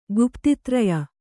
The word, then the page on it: ಗುಪ್ತಿತ್ರಯ: Kannada